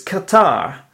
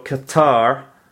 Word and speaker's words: Qatar: In the 9th place, interestingly enough, there is Qatar. Qatar, you know where Qatar is, you know? It's a small, very rich country in the Middle East. Qatar.